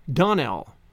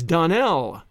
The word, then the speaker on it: Donnell: "Clarice Almira told me that you pronounced our name Donnell." "Now, Miss Shirley, the correct pronunciation of our name is Donnell, accent on the last syllable.